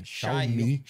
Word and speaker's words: Xiaomi: É, é, não, é, a marca chama Xiaomi.